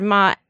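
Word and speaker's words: ma (妈): First tone, the first tone is high and steady. So let's use the word ma.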